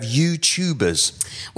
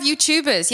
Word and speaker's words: YouTube: We do have YouTubers. Yes, careful. Everyone, you pronounce this wrong, okay? It's YouTube, not YouTube. I've heard this a lot, okay? YouTube. – That's because it's an American pronunciation.